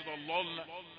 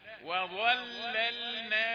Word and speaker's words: وظللنا: وضللنا ما تقولش ضللنا عشان الضاء تعمل ايه ينسحب حكمها على غيرها، لا وضللنا عليهم الغمام، وضللنا قول كده وظللنا عليهم الغمام وأنزلنا عليهم المن والسلوى كلوا من طيبات ما رزقناكم وما ظلمونا ولكن كانوا أنفسهم يظلمون.